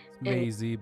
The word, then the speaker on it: Mazzei: Mazzei, but that's as close as most people get, so I applaud you at that.